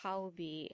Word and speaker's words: Haobi: And the word Haobi, and a lot of people seem to have problem pronouncing my name, but it's Haobi, and it means little fool.